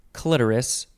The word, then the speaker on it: clitoris: Oral stimulation of the vulva or clitoris, or as some people might say, clitoris. What's your preference? – Clitoris for me. Clitoris. – The emphasis on the first syllable.